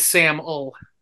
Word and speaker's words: Samul: And by the way, it is Samul. – It's Samul? So I said it wrong? Yes. – Yeah, just rhymes with camel. That's what we always tell people. Camel, camel. Yep.